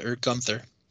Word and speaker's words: Gunther: on Gunther. Sorry. But you said it right the first time.